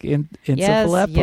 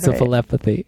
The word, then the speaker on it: encephalopathy: Chronic traumatic encephalopathy. – Yes, yes, yes. We got it. We're talking about Bravo. – I said it right, though. I was really proud of myself. – I am encephalopathy.